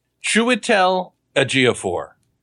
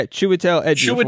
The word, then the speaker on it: Chiwetel Ejiofor: Chiwetel Ejiofor. – Yeah, Chiwetel Ejiofor. – Chiwetel Ejiofor.